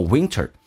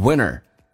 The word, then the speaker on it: winter: 这三个单词可以说成 an in a， an in a winter，在这个我们讲在冬天的时候 winter。同时有的老外也会把这个 winter 说成 winner，为什么呢？因为 NT 组合 T 可以不用发音，这是个偷懒现象，就好像 twenty 说成 twenty 一样的道理，叫做 winter， or you can also say winner， The days are short.